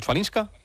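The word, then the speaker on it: Chwalińska: Y mañana la final femenina, a partir de las 3 de la tarde, entre Andreeva, y a ver si lo pronuncio bien, Chvalinska. – Sí, está bien dicho, la verdad.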